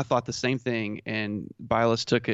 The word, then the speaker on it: Bilas: I think there was one drive that Jay Bilas, mm-hmm. I forget. – It's Bilas. You know, yeah, y'all know how bad I am at pronouncing names, but there was one drive that Shannon.